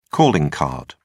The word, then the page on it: calling card: British English